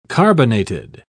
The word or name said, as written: carbonated